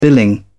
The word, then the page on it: billing: British English